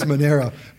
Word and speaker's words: Monero: So Monero, which I was on with a client yesterday and I kept mispronouncing it as Moneda. And Moneda is a financial services company that has nothing to do with Monero, which is both spelled and pronounced differently. It is Monero.